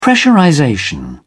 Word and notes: pressurization: British English

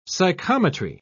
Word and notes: psychometry: British English

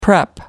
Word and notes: prep: American English

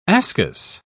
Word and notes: ascus: British English

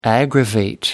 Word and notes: aggravate: American English

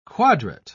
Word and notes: quadrat: British English

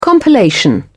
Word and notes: compilation: British English

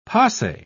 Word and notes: pase: British English

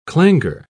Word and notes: clangour: British English

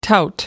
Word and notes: tout: American English

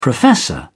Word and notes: professor: British English

